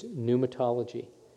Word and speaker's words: pneumatology: It's pneumatology, and the P is silent, unless you're speaking Greek, and then you pronounce the P.